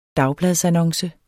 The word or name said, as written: dagbladsannonce